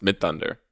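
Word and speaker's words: Midthunder: This is Amber Mid Thunder. I'm assuming it's pronounced Mid Thunder. – It sure is.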